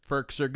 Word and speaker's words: Firkser: Firkser, good.